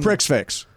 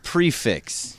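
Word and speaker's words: Prix Fixe: It is spelled Prick's Fix, but it's pronounced prefix.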